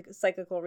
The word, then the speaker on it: psychical: When all of the, like, parapsychology and, like, Society of Psychical Research. I never say that right. Is it cyclical? Cyclical? No, that's circles. – I think you're thinking of psychical.